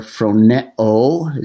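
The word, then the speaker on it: phroneo (φρονέω): I've got it there, phroneo is the way you pronounce that.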